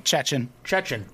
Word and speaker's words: Chechen: He's a Chechen. Is that the right way to say it? – Chechen. – There it is.